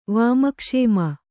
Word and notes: ವಾಮಕ್ಷೇಮ: Kannada

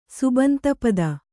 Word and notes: ಸುಬಂತಪದ: kannada

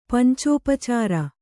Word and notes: ಪಂಚೋಪಚಾರ: pancōpacāra

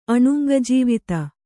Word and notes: ಅಣುಂಗಜೀವಿತ: Kannada

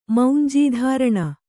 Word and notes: ಮೌಂಜೀಧಾರಣ: maunjī dhāraṇa

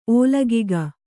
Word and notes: ಓಲಗಿಗ: ōlagiga

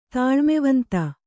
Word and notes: ತಾಳ್ಮೆವಂತ: tāḷmevanta

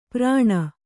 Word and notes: ಪ್ರಾಣ: prāṇa; Kannada

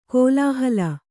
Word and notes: ಕಲ್ಲುಹಾಲೆ: kallu hāle

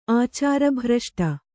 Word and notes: ಆಚಾರಭ್ರಷ್ಟೆ: ācārabhraṣṭe; Kannada

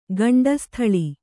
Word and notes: ಗಂಡಸ್ಥಳ: gaṇḍa sthaḷa

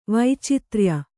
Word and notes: ವೈಚಿತ್ರ್ಯ: vaicitrya

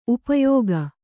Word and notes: ಉಪಯೋಗ: upayōga

